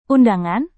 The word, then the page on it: undangan: Indonesian